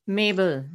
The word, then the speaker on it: Mabel: Mabel Ninan.